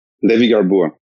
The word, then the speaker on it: Levy-Garboua: Levy-Garboua.